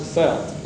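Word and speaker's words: veld: The Dutchmen spell it that way and pronounce it felt, like F-E-L-T.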